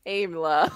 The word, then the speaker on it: Aimla: Like Ayla Secura, but Aimla.